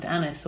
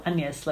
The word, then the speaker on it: Agnes: But he describes her in his will as my daughter Agnes, which in Elizabethan times would have been pronounced Annis or Agnes, like the French.